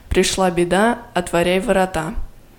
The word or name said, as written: пришла беда отворяй ворота